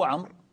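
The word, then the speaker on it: عمرو: نقول لأن عمر ممنوع من الصرف، والممنوع من الصرف لا ينون، فالذي ينون قطعا هو عمر. فالآن تبين أنه عمر ليس عمر، ما احتجنا إلى الواو، لأن هذه الواو ليست جزء من الكلمة ولا تنطق فيها، وإنما هي فقط جاء بها للتفريق اللفظي.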